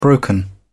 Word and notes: broken: British English

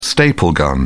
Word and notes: staple gun: British English

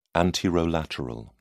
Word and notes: anterolateral: British English

